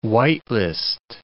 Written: white list

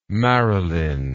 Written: Marilyn

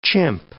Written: chimp

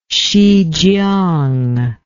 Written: Hsichiang

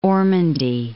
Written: Ormandy

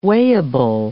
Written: weighable

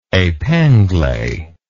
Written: epingle

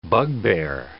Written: bugbear

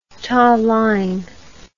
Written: talaing

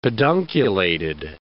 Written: pedunculated

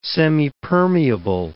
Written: semipermeable

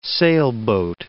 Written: sailboat

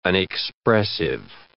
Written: unexpressive

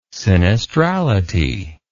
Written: sinistrality

